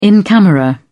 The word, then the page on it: in camera: British English